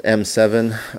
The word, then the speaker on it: Eme7: So my name is M7. I spell it in Spanish. It's E-M-E, so Eme7, right?